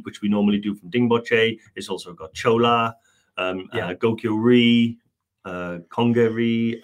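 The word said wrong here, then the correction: Kongma La: and I think I pronounced that wrong. Kongmala, that's it.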